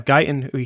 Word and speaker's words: Guyton: I'm assuming Guyton, I believe. – Guyton? Yeah, Guyton.